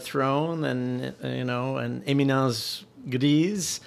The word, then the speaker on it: éminence grise: Let's see if we can't work Dick Cheney in this discussion and talk about an éminence grise.